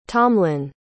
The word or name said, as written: tomlin